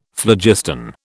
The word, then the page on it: phlogiston: British English